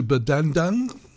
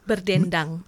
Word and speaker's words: Berdendang: My terrible Western pronunciation, Berdandang. – Berdendang. – Berdendang, yes.